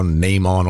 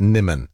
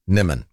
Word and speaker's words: Nimmon: Because if I go Nimon or something, God, it makes it sound like she's out of Star Trek. Somebody's texted saying it's pronounced Nimmon. And you know what? I'll go with that. Nimmon.